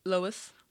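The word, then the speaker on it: Louise: Es Luis, eh, pero de chica. O sea, lo... Lois. Lois, ¿ok? Gluck.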